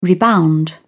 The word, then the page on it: rebound: British English